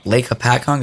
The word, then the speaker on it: Hopatcong: It's in Lake Hopatcong. Did I say that right? – Yeah.